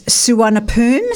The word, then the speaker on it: Suvarnabhumi: It is Suvarnabhumi.